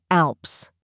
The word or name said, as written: Alps